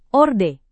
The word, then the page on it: orde: Indonesian